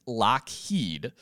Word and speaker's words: Lockheed: So one thing that's interesting to note is that although the name is pronounced Lockheed, it was spelt Lougheed.